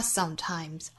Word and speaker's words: sometimes: You know, it can get hard sometimes. Hard 尾部的 d 是同样的发音方法。 You know, it can get hard sometimes. – Sometimes, 重音在第一个音节上 Sometimes, 最后一句 It's the only thing that makes us feel alive.